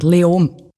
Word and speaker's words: Leoben: Spricht man es Leoben aus? Wie spricht man das überhaupt aus? – Eigentlich fast kein Konsonant. Leom.